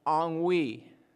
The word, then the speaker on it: ennui: You spell it. You can go home and look this up. E-N-N-U-I. Right? Anybody know that word? It's pronounced ennui.